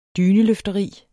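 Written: DYNELØFTERI

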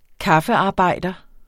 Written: kaffearbejder

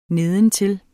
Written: nedentil

